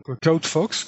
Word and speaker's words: CloudFox: Et donc il s'appelle donc CloudFox, comme ça se prononce, C L O U D F O X.